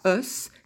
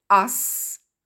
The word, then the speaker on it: us: « Us », faites attention à la prononciation, ce n'est pas « us », c'est « us ». Le « a » qu'on trouve dans « one » ou dans « son ».